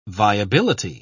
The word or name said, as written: viability